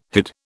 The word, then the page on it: hid: British English